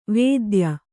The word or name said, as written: ವೇದ್ಯ